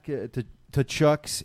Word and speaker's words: Tkachuk: Zach Cassian responds after learning that NHL deemed Matt Tkachuk's hits Tkachuk. – Legal. Tkachuk's hits legal by saying, "Good, now I can do what he does." – The T is silent.